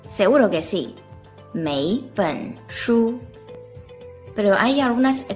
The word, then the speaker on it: 每: Se pronuncia mei.Y normalmente siempre sigue la siguiente estructura.